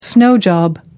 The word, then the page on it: snow job: American English